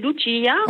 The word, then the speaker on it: Lucia: En italien, c'est Lucia. – Oui. – Et en français, c'est Lucie, normalement.